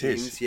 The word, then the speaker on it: Eames: Jack Eames.